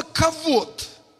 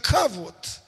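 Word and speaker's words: кавод (כבוד): Это слово «кавод» или «хавод», но чаще произносят как «кавод».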